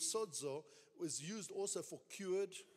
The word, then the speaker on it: sozo: In Strong's number G4982 is a word spelt S-O-Z-O. It's pronounced sozo.